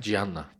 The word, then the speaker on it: Gianna: Daha sonra yapılan araştırmalar sonucunda işte sadece 13 yaşındaki Gianni, doğru telaffuz ettim herhalde. – Gianna. Gianna.